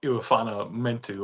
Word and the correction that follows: Melifonwu: Yeah, Melifonwu. – Yeah, Melifonwu.